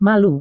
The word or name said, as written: malu